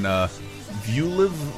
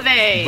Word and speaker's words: Vuelve: Terraformed by shellac in Vuelv. Oh, how do you say Vuelv? That? – Vuelve.